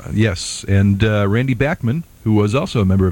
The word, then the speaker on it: Bachman: I would like to note for those wondering, that you were pronouncing it Bachman. – Mm-hmm. – And that is how the Canadians pronounce the name. Yes. Bachman.